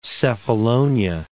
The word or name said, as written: Cephalonia